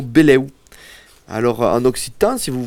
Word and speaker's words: benleu: Alors, on prononce beleu et pas benleu. Parce que ça s'écrit B-E-N-L-E-U. Et on va pas prononcer benleu, on va prononcer beleu.